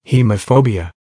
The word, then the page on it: hemophobia: American English